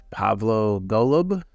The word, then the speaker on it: Pavlo Golub: And today I have Pavlo Golub.